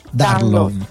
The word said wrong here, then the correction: Danlos: con la V doppia, che sarebbe poi no, con la D di domodossola, D-A-N-L-O-S, Danlos. – Ah, Danlos.